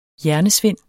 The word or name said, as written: hjernesvind